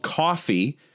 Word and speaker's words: Coughy: They're called Coughy. It's spelled like cough, like with a Y after it.